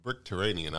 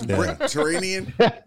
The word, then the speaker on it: Brickterranean: Bricktranian. I said it wrong there. – So it's Bricktranian? – Like, yep, Bricktranian.